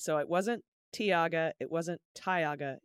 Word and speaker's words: Tioga: I totally don't want to be that person, but Tioga County is pronounced Tioga, rhymes with toga.